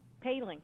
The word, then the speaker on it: Paling: Paling.